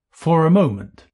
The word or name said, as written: for a moment